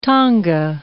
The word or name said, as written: Tonga